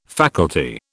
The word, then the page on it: faculty: British English